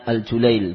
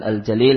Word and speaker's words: Al-Julail: Karena seorang penulis bernama Abdul Aziz Ibn Nasir Al-Julail, itu bacanya Al-Julail, bukan Al-Jalil, namun bacanya Al-Julail.